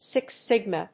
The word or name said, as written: six sigma